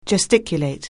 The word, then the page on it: gesticulate: anglais